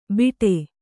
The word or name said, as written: ಬಿಟೆ